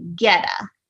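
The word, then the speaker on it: goetta: And it's just added a little bit of that oat flavor to the sausage, but it's spelled not like it looks. It's spelled like a G-O-E-T-T-A, but in Kentucky, we say geta.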